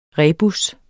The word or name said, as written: REBUSSEN